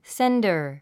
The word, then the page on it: sender: séndə r; センダァ